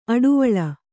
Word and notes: ಅಡುವಳ್ಳ: aḍuvaḷḷa

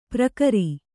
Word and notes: ಪರಿಕರ: parikara

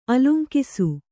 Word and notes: ಆಲಂಗಿಸು: Kannada; ālaŋgisu

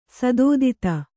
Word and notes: ಸದೋದಿತ: kannada

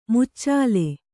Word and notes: ಮುಚ್ಚಲ: Kannada

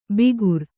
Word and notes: ಬಿಗುರ್: bigur

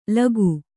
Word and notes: ಲಕ್ಕ: Kannada

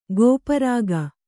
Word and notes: ಗೋಪರಾಗ: gōpa rāga

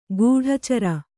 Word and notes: ಕಡೆಚೋರಿ: kaḍecōri

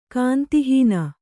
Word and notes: ಗೊಂದಹಣ್ಣು: gonda haṇṇu; kannada

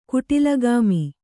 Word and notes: ಕುಟಿಲಗಾಮಿ: kuṭilagāmi

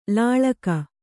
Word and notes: ಲಳಗಿ: laḷagi

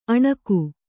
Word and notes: ಅಣುಗಿ: aṇugi

